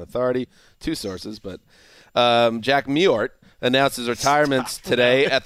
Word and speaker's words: Mewhort: Meward? – I think it's Mewhort. – No. That's in Mewhort. – No. Mewhort. The W is basically silent.